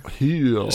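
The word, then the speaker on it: Hüll: Heel. – So it's spelled like whole, but has the umlaut over the U, so whole.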